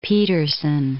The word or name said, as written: Pedersen